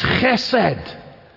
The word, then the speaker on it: chesed: It's chesed. And you gotta use the blessing of being Scottish with that CH sound at the beginning.